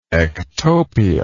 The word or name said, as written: ectopia